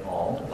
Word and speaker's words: Aung: My name is Tin Lin Ong. Last name is spelled A-U-N-G.